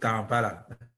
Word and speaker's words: Kampala: The organization is in Uganda and Kapla, Uganda. Did I say it right? Is it Kapla or Kaplan? – We are living in a capital city of Uganda called Kampala.